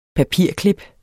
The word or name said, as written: papirklip